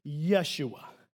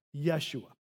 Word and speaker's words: Yeshua: From Yeshua, we get the name Joshua. Okay, that's the Hebrew term there. But they didn't have the J sound, so it's just Yeshua, Yeshua.